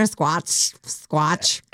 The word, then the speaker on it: Squatch: Sydney says, when we're at the Dr. Squash shoot, sorry, I mispronounced that. Let me try again. I was so excited about it. When we were at the Dr. Squash suit. – What? – Dr. Squatch.